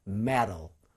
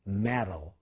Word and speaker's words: metal: 그런데 지난번에 얘기했지만 강세가 없는 모음 앞에 T 사운드가 오면 리을로 바뀌어요. 그래서 metal, metal 이렇게 되죠.